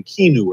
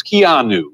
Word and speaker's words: Keanu: I was saying Keanu or something. It's Keanu. Is that correct? Yes.